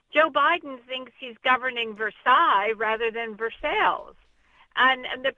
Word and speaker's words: Versailles: They're pronounced Versailles mainly because of the early Scots-Irish settlers. The Scots had a tendency to overemphasize the second syllable and not the first.